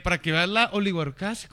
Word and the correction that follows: oligarquía: ¿cómo se dice? oligarquía. oligarquía de este país, güey.